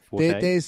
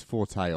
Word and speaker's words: Forte: How do you say his last name? Forte? – There's two ways to say it. It's Forte or Fort. Either way.